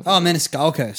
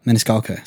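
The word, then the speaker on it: Maniscalco: He's been played by Sebastian how do you say that, guys? Meniscalco. – Sorry, I'm blind. – No, that's right. – Meniscalco.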